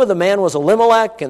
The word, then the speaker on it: Elimelech: We always Americanize these pronunciations, right? We say Elimelech. Probably in Hebrew it would be more like Elimelech, okay?